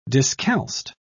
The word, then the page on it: discalced: British English